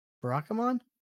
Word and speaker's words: Barakamon: Barakamon. Yeah, nope. That's how it is.